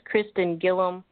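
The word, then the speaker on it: Christin Gillam: Again, my name is Kristen Gillum.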